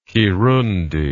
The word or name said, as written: kirundi